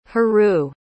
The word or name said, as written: harue